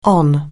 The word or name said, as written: on